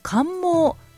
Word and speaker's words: 冠毛: 花の後にまるでおじいさんのひげのような白い冠毛、冠の毛と書いて冠毛というんですが、そういう状態に花柄がなるということでね、そこから来ているそうなんです。